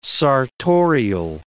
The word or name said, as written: sartorial